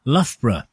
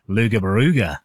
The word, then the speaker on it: Loughborough: I'm currently in the kitchen making Sunday dinner for six as my daughter is up visiting me from Loughborough, which I am advised is not pronounced Luga Baruga, but that doesn't stop me.